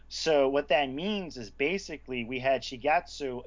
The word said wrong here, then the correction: Shigesato: Shigesato? – Basically, you have this famous writer.